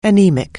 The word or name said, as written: anaemic